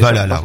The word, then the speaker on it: Valhalla: Si, si, c'est Valhalla, ouais, très bien.